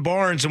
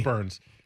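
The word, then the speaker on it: Burnes: Woodruff and Barnes, and we're like, okay. – Burns.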